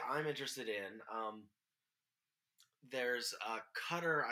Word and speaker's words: Qatar: Now, Qatar is how we're pronouncing it. – Yes, that's my understanding.